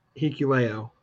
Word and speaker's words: Hikuleo: Is it Hikuleo? Is that how you pronounce it? – Hikuleo. – Okay.